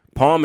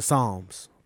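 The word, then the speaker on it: Psalm: Palm is the last one, I believe. – Yeah. Or is it Psalms? Because it's spelled like the Bible.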